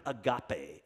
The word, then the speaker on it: agape: It's the word agape. A-G-A-P-E is how you'd spell it in English.